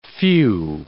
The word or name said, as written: few